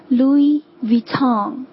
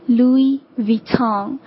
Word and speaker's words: Louis Vuitton: Louis Vuitton。 OK， Louis Vuitton。